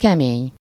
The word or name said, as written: hard